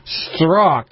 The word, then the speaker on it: Strzok: Peter Strzok was escorted from the well, come on, you say that.